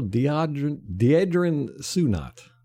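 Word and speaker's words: Deadrin Senat: Defensive tackle Deidrin Sunat. – Yes. – Good. – Good job. You said his name right, too.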